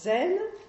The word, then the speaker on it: Zen: Donc cette école met l'accent sur les pratiques de Dhyana Et c'est une école qui s'est donc transmise en Corée, où le terme Chan se prononce Son. Au Japon, où il se prononce Zen.